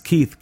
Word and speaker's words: Keith: And my first name is Keith, K-E-I-T-H. And my family name is spelled P-E-R-R-O-N. That's P-E-R-R-O-N.